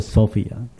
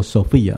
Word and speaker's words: Sophia: 希腊人习惯称智慧，智慧的希腊文叫 Sophia，我们中间有没有姊妹们英文名叫 Sophia，英文读作 Sophia，在希腊文里面读作 Sophia，那直接是希腊文这个字。